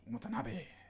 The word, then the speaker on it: Watanabe: Watanabe. – Watanabe. – Yeah, that's how you say it.